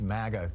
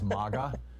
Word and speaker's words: MAGA: I have heard, by the way, apropos of the pronunciation of MAGA, that some Republicans are saying it shouldn't be pronounced MAGA because that sounds too much like maggot, and therefore they want it to be pronounced as MAGA, you know, which sounds kind of like mama or something.